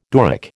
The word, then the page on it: Doric: American English